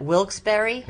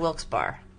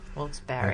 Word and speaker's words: Wilkes-Barre: So that's how they pronounce that, Wilkes-Barre. – Yes. – Instead of Wilkes-Barre. – Yes. – Okay, Wilkes-Barre.